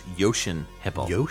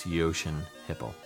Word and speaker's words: Jochen Hippel: I believe it's Jochen Hippel. – Jochen. Okay, well, instant clarification. Apologies for my terrible pronunciation. – Yeah, I believe it's Jochen Hippel.